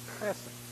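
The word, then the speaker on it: chesed (חסד): It is pronounced something like this, chesed.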